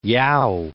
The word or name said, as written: Yao